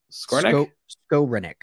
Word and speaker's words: Skowronek: How do you pronounce his name, by the way? I always screw that up. Skowronek. Skowronek? Okay. Skowronek and Odell.